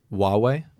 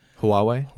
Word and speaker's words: Huawei: you say Huawei, like Huawei, New Jersey? – Huawei? Huawei? – I don't know.